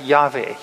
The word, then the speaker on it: Yahweh: And it's often pronounced by us as Yahweh. You've heard that. We sing songs like that, Yahweh.